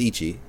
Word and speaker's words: Ichi: Uh, and one is pronounced as Ichi, and in Japanese, U1 is a nickname given to people named Yuichi in Japan.